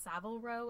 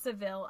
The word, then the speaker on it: Savile Row: So in 2006, they end up starting The Row, named after, I think it's pronounced Savile Row in London. – Yeah. – And it's a Savile.